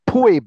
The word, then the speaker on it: Phoebe: And by the way, that's spelled P-H-O-E-B-E. Poe, in Latin, you would say Poebe. That's how you would say that name.